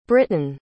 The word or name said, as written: brittain